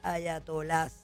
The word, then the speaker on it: ayatolás: Dicen que el presidente Trump dice, la llave del suministro no está en manos de los ayatolás. Eso ayatola. Es lo que... Ayatolás. Tiene acento en la segunda. Yo siempre he dicho ayatola, pero eso es una buena pregunta para ChatGPT.